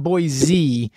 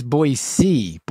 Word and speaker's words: Boise: It's not Boise. It's Boise. Boise. They get very annoyed in Boise when people say Boise.